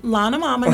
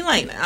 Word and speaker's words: Lana: Lana Mama, okay? And if I mess up again, be like, no, it's Lana Mama, not Lena. Please stop calling me Lena.